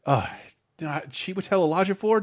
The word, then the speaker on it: Chiwetel Ejiofor: Ladies and gentlemen, Chiwetel Ejiofor, did I get his name right? – Yeah, that was pretty good.